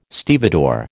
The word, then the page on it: stevedore: American English